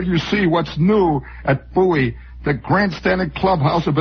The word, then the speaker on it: Bowie: They're up and running Monday, Friday, January 11th at Bowie Race Course. Bowie Race Course. By the way, it says in here, rhymes with Dewey.